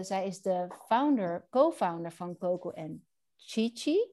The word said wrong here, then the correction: Cici: Zeg ik het goed? Sisi? Sisi. Coco en Sisi.